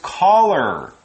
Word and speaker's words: collar: That word is collar. Collar. C-O-L-L-A-R. Now, this isn't like a caller, someone who calls you on the telephone, although it's pronounced the same, it's spelled differently.